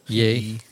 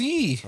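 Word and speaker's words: Ye: Yay. – Yay. – It's pronounced yay. I just found out it's pronounced yay, but everybody. – I thought it was yee. – Yeah, I thought it was yee too,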